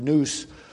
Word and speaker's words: nous: That's the word nous. It sounds like a noose that you would put around somebody's neck. It's spelled N-O-U-S in the English, if we were to spell it.